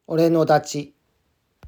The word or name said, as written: Ore no Dachi